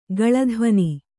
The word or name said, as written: ಗಲಧ್ವನಿ